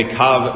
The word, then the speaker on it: kav: So when we get the circumference of 30 to 10, it's called a kav misaviv. Now that word kav is spelled kava. It's spelled kuf vav hey, and it's pronounced kav, right?